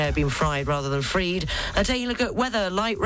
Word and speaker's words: Fried: And the F FTX founders Sam Bankman Freed and not Fried, as I apologise for repeating and mispronouncing his name earlier on in the show, has been arrested in the Bahamas.